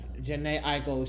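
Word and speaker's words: Jhené Aiko: Janine Akeo dropped her, I might have said it wrong. I do apologize. – Janae Aiko, excuse me. Thank you, executive producer, for correcting me.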